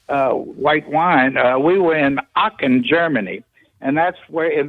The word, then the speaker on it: Aachen: In the cathedral in Aachen. – Spell it. – A-A-C-H-E-N, Germany.